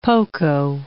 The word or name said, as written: poco